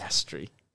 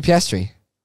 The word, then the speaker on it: Piastri: Piastri. – Piastri. No. – Who's Piastri? – Oscar Piastri. Do you not know Oscar Piastri? – Piastri. – It's Piastri. – Piastri. – Piastri. – Piastri. – Piastri.